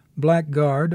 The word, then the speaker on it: blackguard: Since these lowly menials were always ragged and usually extremely dirty, their portion of the train was jocularly called the Black Guard. Thievery was common among them, and they were generally unscrupulous, and thus blackguard, or blackguard, as the British pronounce it, came to mean any villainous person or one who spoke in an off-color manner.